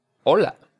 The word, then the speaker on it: hola: Remember that O is just a simple O. Don't drag it out into an O, like an English O. Hola. O. – Hola.